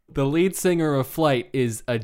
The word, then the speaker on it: Flyte: Flight, it's spelled F-L-Y-T-E. It's a reference to Sebastian Flight from Brideshead Revisited.